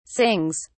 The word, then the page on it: sings: British English